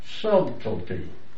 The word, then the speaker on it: subtlety: That's the way you pronounce the word. Subtlety.